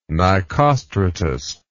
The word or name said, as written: Nicostratus